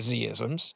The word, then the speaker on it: Z-isms: Well, here in the States, I have a preference. I pronounce it Z-isms.